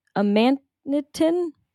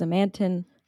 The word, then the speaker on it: amanitin: But essentially the idea was that the toxin alpha amanitin, I was always pronouncing it as amantin. There's an extra I there that really threw me off.